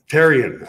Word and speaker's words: Therien: Is it, is it Theron or Taryn? – Therien, actually. – Therien. Yeah, it's French Canadian.